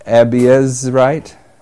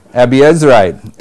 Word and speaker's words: Abiezrite: and that pertained to Joash of the Abzarite." Somebody want to pronounce that for me? I'm in verse 11. Joash the Abiezrite. Abiezrite.